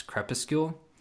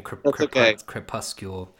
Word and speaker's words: crepuscule: How does that then, by extension, how does that work when you're writing something by, oh, and also thank you for teaching me how to pronounce crepuscule. – Oh, sure. – didn't know. I was saying crepuscule.